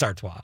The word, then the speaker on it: Artois: Stella Artois. Am I saying that right? Artois. – Artois. – Artois. – I don't know. – It's Artois. And Berghoff Bach.